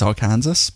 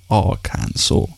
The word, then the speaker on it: Arkansas: I always thought it was Arkansas, but put it onto Google, and apparently the short answer is there is a law in Arkansas that it has to be pronounced Arkansas.